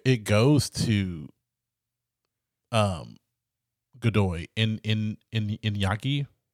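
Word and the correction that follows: Iñaki: Inaki. Inaki. – Are you sure? We double check. I don't want to say it wrong. I mean, let's respect the man. – Inaki. Inaki. – Yeah, Inaki Godoy.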